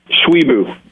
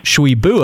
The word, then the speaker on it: Tshiebwe: Oscar Tshiebwe, I believe that's how we pronounce it, right? – Tshiebwe is how I've heard him say it. – Tshiebwe. Okay, there we go. So we got an answer on that.